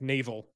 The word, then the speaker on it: Naval: Naval, like naval, like but pronounced Naval.